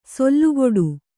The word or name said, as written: ಸೆಲೆಕೊಡು